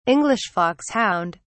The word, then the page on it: English foxhound: British English